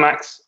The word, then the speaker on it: Max: Um, so I'm Max.